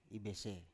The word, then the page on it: ibese: Biak